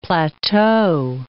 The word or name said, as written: plateau